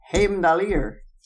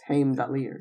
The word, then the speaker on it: Heimdallr: Like, I've always pronounced it Heimdall. – Well, yeah, but it's Heimdallr.